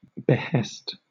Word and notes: behest: British English

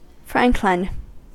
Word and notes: franklin: American English

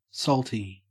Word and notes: salty: Australian English

